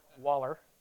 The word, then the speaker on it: wallow: Piglets Waller, that's another English word, Waller, W-A-L-L-O-W, is pronounced Waller.